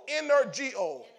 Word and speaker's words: energeo: E N E R G E O, energeo, or actually it's pronounced energeo, energeo, ener or energeo. The, the G is a hard G.